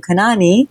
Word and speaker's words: Kanani: I'd get teased because of my last name, Kanani, rhymes with,